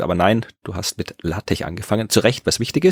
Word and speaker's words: LaTeX: Also LaTeX geschrieben, aber LaTeX ausgesprochen.